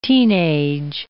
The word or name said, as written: teenage